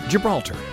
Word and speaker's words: Gibraltar: The marriage is legal in the UK because Gibraltar, say that for me. – Gibraltar. – Did I say that right? – Yeah, you did.